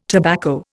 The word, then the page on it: tobacco: Indian